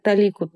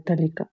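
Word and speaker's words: толика: Игорь, я правильно поставила ударение в слове «талика»? – Слушай, я сижу, гуглю словарь сейчас. Я Толику, конечно, привык с детства, но покажется ты права.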